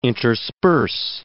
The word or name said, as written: intersperse